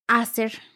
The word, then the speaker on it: Acer: que en algunos países a lo mejor se pronuncia Acer. ¿Cómo se pronuncia en México? – Hacer. Ah, Yo la he escuchado como hacer.